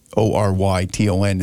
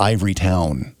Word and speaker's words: Ivoryton: Ivoryton is the right way to spell it. They called it Ivory Town.